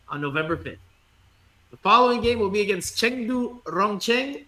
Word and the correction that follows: Rongcheng: I just did it in Spanish pronunciation, Rongcheng, on November 8th, so 3 days later.